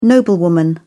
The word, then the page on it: noblewoman: British English